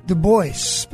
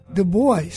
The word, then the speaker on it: Du Bois: Du Bois, Pennsylvania, not Du Bois. Du Bois, he clearly says it's pronounced the American way, not the French way.